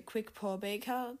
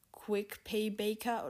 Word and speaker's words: Quickpaw: Zum Beispiel dass Karak Karak ausgesprochen wird und, und nicht Karak oder dass äh, Jeffrey Quickpaw Baker, dass der so heißt und nicht äh, Jeffrey Quick Pay Baker oder so ähnlich. Quick Pay Barker.